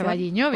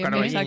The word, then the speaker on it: O Carballiño: O Carballiño, bien, o Carballiño.